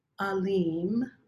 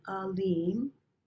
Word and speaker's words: Alim: The first word is Alim, Alim, with a long E, Alim. Alim is omniscience, this omniscient view and all-seeing and all-knowing of God.